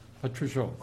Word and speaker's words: Patrushev: I'm not sure, is it Patrushev or Patrushev? – Patrushev. – Stress on first syllable.